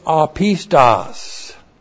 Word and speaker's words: apistos: And then we have the adjective apistos. Apistos. A-P-I-S-T-O-S. Now this is a compound word. This is the alpha privative. The alpha privative means no. This is pistos from pistis, faith. So, apistos literally means no faith.